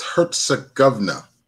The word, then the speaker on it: Herzegovina: Herzegovina. – Over there where they be fighting it. – Thank you. – Say it again. – It's Herzegovina.